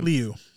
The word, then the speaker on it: Liu: Liu. – Simu Liu.